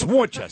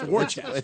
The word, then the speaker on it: Worcester: It is spelt W-O-R-C-E-S. What says Worcester about that? It's Worcester. It's Worcester.